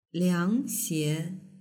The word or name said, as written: liangxie